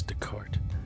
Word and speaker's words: Descartes: is asking him about Descartes. Did I say that correctly? – I believe the S is silent. I believe it's Descartes.